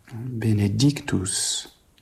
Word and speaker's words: Benedictus: Fort sur la deuxième syllabe qui n'est pas accentuée de Benedictus sur le béné. Cela s'explique en référence à l'original constitues dont la deuxième syllabe, elle, est bien accentuée. Donc ici, il ne faut pas trop souligner cette deuxième syllabe et puis il faut réserver davantage l'intensité sur l'accent. Benedictus, c'est-à-dire la troisième syllabe du mot.